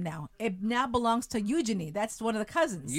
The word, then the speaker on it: Eugenie: Anyway, but they pronounce it Eugenie. – Eugenie.